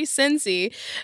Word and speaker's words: Sinise: David even once showed up to Melanie Griffith's apartment in the middle of the night where Gary Sinise was house-sitting at the time. – Gary Sinise.